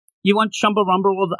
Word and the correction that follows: Chumbawamba: Say it right. Do it again. Try again. – Chumba Wumba Rolled.